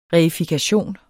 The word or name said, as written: reifikation